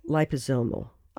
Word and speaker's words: liposomal: they call it a smart liposomal nanosphere. So it's— That's pronounced liposomal.